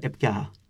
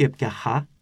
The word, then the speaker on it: έπιαχα: Προφέρουν κάτι σαν ε, δασί πνεύμα, ανάμεσα, δασί τριβόμενο, ανάμεσα στα φωνήντα έπιαχα. Α, μπαίνει χ. – Όχι, έπιαχα, έπιαχα. Είναι όπως το γερμανικό το, ή το αγγλικό το H.